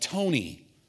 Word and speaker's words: Tony: Like for me, the German rendering of Tony is T-O-N-I. But my name is spelled T-O-N-Y in English.